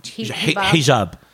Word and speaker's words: hijab: and everybody is in jihad. Wait, how do you say it? – Hijab. – Hijab.